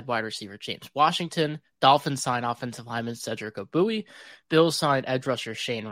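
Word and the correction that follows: Ogbuehi: I don't know why it gives me joy when you mispronounce a name, but it does a little bit. It does. Obuehi. Like Baba Booey.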